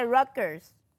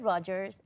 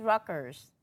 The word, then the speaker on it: Rutgers: Se escribe Rogers, pero se dice Rutgers. Así es. Conozco gente, muchas amistades que han estudiado ahí. Y yo le decía, ¿tú estudiaste en Rutgers? Me dice, no es Rutgers, es Rutgers.